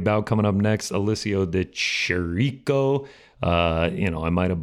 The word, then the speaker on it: De Chirico: I believe it is just a Chirico. De Chirico. – Yeah. Alessio De Chirico. There it is. Saying it properly.